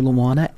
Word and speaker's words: Luauna: Get on that website and write to me, Dr. Luana at A Touch From Above. How do you spell my name? L-U-A-U-N-A.